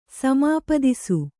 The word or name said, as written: ಸಮಾಪ್ತಿಸು